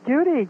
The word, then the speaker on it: Judy: No, it's Judy, J-U-D-Y. – Oh, all right. Well, there you go. It has A-I-D-A.